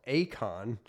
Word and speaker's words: Eikon: I mean, this article actually appears in what is called Akon, not the rapper. This is spelled differently, E-I-K-O-N, and I looked up what the Greek word for Akon means, and it's an image.